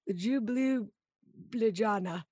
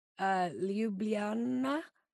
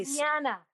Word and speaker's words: Ljubljana: J in our country is pronounced like y. – Ah, okay. – So now do it again. – Ljubljana.